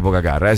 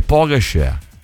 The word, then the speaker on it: Pogačar: Poi non si dice Pogačar, si dice Pogačar.